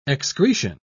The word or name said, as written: excretion